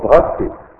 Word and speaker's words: бхакти: Слово бхакти, бхакти, но ха вот она не читается.